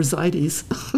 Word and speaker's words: Zaidy's: Or Zaidi's. – Oh, Zaidi's, yes. – We've been saying it wrong. – However you'd like to pronounce it. When people ask, I say Zadie Smith. Are you familiar? And they say, yes. I said, you could pronounce it that way if you'd like.